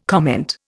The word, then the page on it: comment: Indian